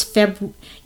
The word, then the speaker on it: February: I'll leave out the R. – It's very hard to pronounce February.